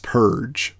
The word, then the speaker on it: Purg: I believe they said it's pronounced Purge, but they spelled it incorrectly.